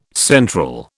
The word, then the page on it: central: British English